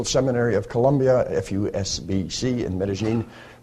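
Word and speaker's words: Medellín: I learned that if you're down if you live in Medellin, you pronounce it Medellin. And so you can tell a local from somebody from Bogota.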